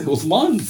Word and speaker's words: Uthman: They were pronouncing the names properly. Uthman.